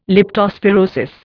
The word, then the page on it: leptospirosis: Indian